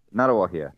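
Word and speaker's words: Ngāruawāhia: I've really researched this because there's all places that I can't pronounce. Nagara. Help me here. – Narawahia, which is just down the road from where I live here in the Waikato.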